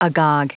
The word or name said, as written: agog